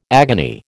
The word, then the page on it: agony: American English